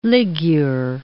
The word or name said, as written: ligure